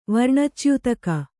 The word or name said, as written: ವರ್ಣಚ್ಯುತಕ